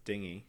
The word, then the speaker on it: dengue: It's spelled like dengue, but it's dingy.